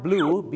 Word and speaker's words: blew: ถ้า blue b l e w ออกเสียงเหมือนสีฟ้าหรือน้ำเงิน